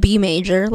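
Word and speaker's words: Beimaejor: It's just B major, like the key, but he just spells it funny for whatever reason.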